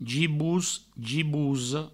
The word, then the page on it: gibos, gibosa: gibous; gibouso